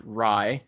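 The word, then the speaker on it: Rhye: Is I believe the artist's name is pronounced Rye. It's R-H-Y-E.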